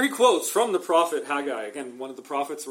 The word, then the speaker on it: Haggai: Here, he quotes from the prophet, I always struggle to say this, I was taught to pronounce this Haggai, but it's Haggai, literally.